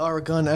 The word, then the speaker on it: Oregon: Yeah, Oregon. Emphasis on the gun.